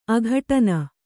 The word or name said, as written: ಅಘಟನ